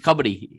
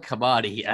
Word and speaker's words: kabaddi: And Stu, it's kabaddi. It's not kabaddi, all right? – Oh, sorry, so, I'm Australian.